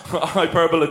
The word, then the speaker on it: hyperbole: I think, you'll find that hyperbole does not rhyme with Super Bowl.